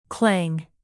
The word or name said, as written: clang